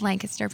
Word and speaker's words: Lancaster: I realized I was saying it wrong. It's Lancaster, Pennsylvania, with Joshua and Bailey, and I'm so excited to meet you guys.